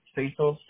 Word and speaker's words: Cecil: It's Cecil, C-E-C-I-L.